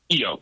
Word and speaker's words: Keyo: Keyo, it's like a key with an O on it.